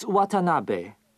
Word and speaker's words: Watanabe: It's Watanabe. W-A-T-A-N-A-B-E.